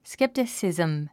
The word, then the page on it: skepticism: sképtəsìzm; スケプテスィズム